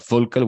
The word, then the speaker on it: Völkl: I have an interesting guest today, Nick Mitchell, who's the promotions manager for Völkl. That's the way you pronounce it in German, I would guess, but Völkl, we say here.